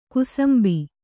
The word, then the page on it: ಕುಸುಂಬಾ: Kannada